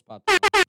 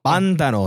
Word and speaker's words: Pantano: April 22nd, Absurd Planet, Circus of Books, El Silencio del Patano. Did I say that right? – I don't know. What's Pantano? Pantano, excuse me.